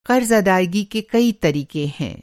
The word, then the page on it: अदायगी: ادائیگی